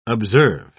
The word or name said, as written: observe